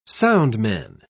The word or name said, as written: soundman